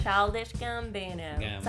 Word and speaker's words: Childish Gambino: Esta semana tenemos el álbum nuevo de The y de Mr. Gambino. ¿Lo pronuncie bien? – Childish. – Childish. – Childish Gambino.